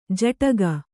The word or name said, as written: ಝಾಡಿಕೆ